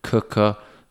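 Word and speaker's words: Quooker: Cooker, spelt quicker, but pronounced cooker, is just the way they should sell it.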